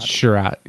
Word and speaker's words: Chiarot: How do you say his name? Chariot. – Chariot. Yeah.